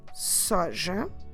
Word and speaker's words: soja: If we say a soy drink, soy is the word soja. Soja. Como se escreve soja? S-O-J-A.